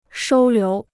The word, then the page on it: 收留: shōu liú